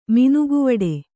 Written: ಮಿನುಗುವಡೆ

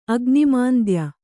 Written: ಅಗ್ನಿಮಾಂದ್ಯ

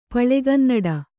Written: ಪಳೆಗನ್ನಡ

